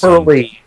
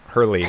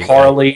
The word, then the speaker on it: Hurley: Hurley, H-U-R-L-E-Y, Dan Hurley. – Did I say it wrong? Hurley. – Harley, Hurley. – Sorry, I pronounced it wrong, but I know it, Hurley, like the surfboard company, right?